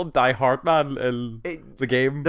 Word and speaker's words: Hardman: He himself uses only two syllables. It's Hardman. And that's what Cliff calls him as well.